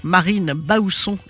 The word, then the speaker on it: Marine Baousson: Donc, Marine Bausson, exactement comme ça se prononce.